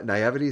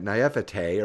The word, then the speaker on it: naivety: Oh boy, it was a rotten treachery, playing on the innocence and naivety, uh, na- naivety is how you say it, naivete or whatever of the techies.